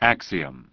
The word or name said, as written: Axiom